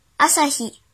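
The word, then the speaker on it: 朝日: 训读的时候读作阿萨阿萨阿萨，比如朝阳阿萨希阿萨希阿萨希，写成日语汉字是朝加日。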